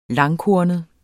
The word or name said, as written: langkornet